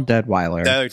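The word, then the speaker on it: Deadwyler: Danielle Deadweiler for Till.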